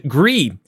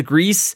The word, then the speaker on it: Gris: It's French for gray, so you don't pronounce the S, okay? So that's, you're not supposed to, everybody says Greece, but it's not like the John Travolta, Olivia Newton-John thing.